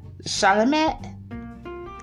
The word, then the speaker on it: Chalamet: Timothy Chalamet, that's his name.